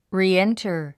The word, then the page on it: reenter: riːéntər; リーエンタァ